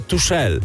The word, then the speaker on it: Tuchel: No ja sobie pomyślałem, że byli na autostradzie do półfinału, do finału, ale wylądowali w piekle, więc pomyślałem sobie Highway Tuchel. – Albo Tuchel. – A ty wtedy powiedziałeś, że on się wymawia Tuchel.